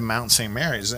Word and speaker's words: Mount St. Mary's: I, I uh, did, so I saw you went to Mount St. Mary's.